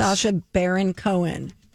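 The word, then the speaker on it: Sacha Baron Cohen: Sasha Baron Cohen. Is that how you say it? – You're doing good.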